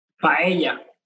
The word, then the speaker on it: paella: Paella. How do you say it? – Paella.